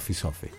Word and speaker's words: Safi: Diciamo sì, si legge, si scrive Safi, ma chiaramente il cantante arabo che lo canta, Mohamed Ziad Trabelsi, straordinario musicista tunisino che appunto vive in Italia dal 2000, sì. – Insomma lo pronuncio Sofi.